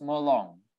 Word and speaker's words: Mulong: Note, Mulong, a Penan word, is pronounced Mulong with stress on the second syllable.